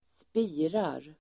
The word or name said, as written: spirar